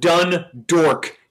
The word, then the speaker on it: Dundalk: I was calling them Dundork, D-O-R-K. – Oh, but that is how you pronounce it.